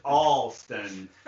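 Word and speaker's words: Allston: And we're in Austin, the Austin neighborhood of Boston, which is kind of punk. – The Austin neighborhood of Boston? – Well, you pronounce it Alston, not Austin like Texas.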